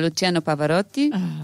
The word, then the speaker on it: Luciano Pavarotti: C'est aussi un jour des fameuses naissances, telles que Luciano Pavarotti.